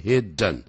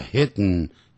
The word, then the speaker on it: hidden: 鼻音用 hidden, 不要念成 hidden, 叫 hidden, hidden, 咬东西 bite, bit, bitten, 不要念成 bitten, 叫 bitten, 用鼻音这样出来啊。